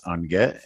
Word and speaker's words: Ungit: My name is Lewis Unget, and you can find me on Twitter at @IamUnget, and that's spelled UNGIT.